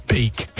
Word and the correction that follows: Van Der Beek: James Van Der Beek.